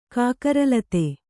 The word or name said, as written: ಕಾಕರಲತೆ